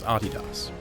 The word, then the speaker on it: Adidas: And by the way, that is indeed how you pronounce Adidas.